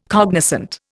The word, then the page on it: cognoscente: Indian